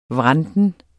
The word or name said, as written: vranten